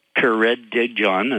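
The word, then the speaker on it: Caradog: Well, his name is John Caradigjohn, and that's with an accent on the dig, Jones.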